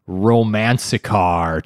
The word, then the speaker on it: Romance Car: So in 1957, the Odakyu Electric Railway introduced the 3000 series SE Romance Car train. I don't know if I'm saying that right at all. – Romance car. It's romance. Just romance plus romance. Car.